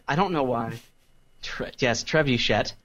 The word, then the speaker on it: trebuchet: That's how it's pronounced, isn't it? – I'm pretty sure. – Mm-hmm. – The Trebuchet. – That's it's French. – It's... It's what it sounds like when an Anglo tries to speak French.